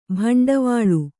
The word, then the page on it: ಭಾಂಡವಳು: Kannada